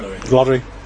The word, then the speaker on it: Lorre: Is that how you pronounce it? Lorre. – Yeah. – Lorre. – Hello, you. R-I-E. – No. – L-O-R-R-E. – Peter Lorre. – Lorre. – Peter Lorre. That's all, yeah, Peter Lorre.